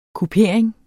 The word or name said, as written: kupering